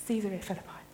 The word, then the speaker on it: Caesarea Philippi: Caesarea Philippi. Excellent. I was looking online. How does this pronounce? Caesarea Philippi.